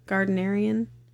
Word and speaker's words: Gardnerian: Did I say that right? Gardnerian Wicca? – Yeah.